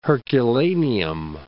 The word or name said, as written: Herculaneum